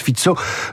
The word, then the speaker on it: Fico: De quoi offrir à, Robert euh, Fizzo, on écrit Fico mais on prononce Fizzo, le fondateur de ce parti, l'opportunité de former une coalition dont les orientations pro-russes risquent de peser sur toute l'Union européenne.